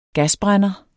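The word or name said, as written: gasbrænder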